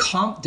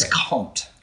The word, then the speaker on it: Comte: Oh, Comte de Saint Germain. – So it's just Comte. – Okay. It's Comte.